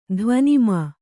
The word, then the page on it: ಧ್ವನಿಮಾ: dhvanimā